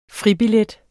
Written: fribillet

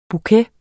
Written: bouquet

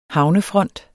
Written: havnefront

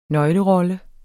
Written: nøglerolle

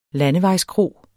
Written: landevejskro

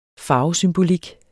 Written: farvesymbolik

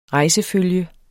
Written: rejsefølge

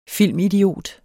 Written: filmidiot

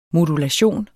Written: modulation

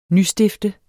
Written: nystifte

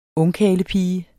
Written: ungkarlepige

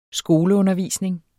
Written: skoleundervisning

